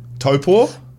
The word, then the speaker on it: Taupo: Taupo.